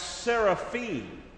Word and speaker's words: seraphim: The reason we pronounce, or I pronounce it, seraphim in the Hebrew, at the end of a word in the Hebrew, we call it a hirik yod mei.